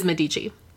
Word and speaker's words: Medici: Is it Medici? I feel like we got somebody that emailed in and said we were pronouncing it wrong. – It was Medici. Yes, that's what we said. Okay, it wrong. – Cool. It is Medici.